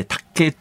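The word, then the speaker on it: 磔刑: リスト教のお祭りで有名なお祭りがあるんですけど、はい、十字架ありますね、うん、あのイエス・キリストが、あ、まあ、タッケーっていう、タッケーってこれ貼り付けの刑のことをタッケーっていうんですが、タッケーの立つっていうのが、立っていうのが漢字ですごい難しいんですけど、まあ、貼り付け刑と漢字で書いてタッケーって読むんですが、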